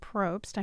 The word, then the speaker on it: Probst: And talk about the guy, his name is Robert Probst, I hope I'm pronouncing that correctly. – That's right, yeah.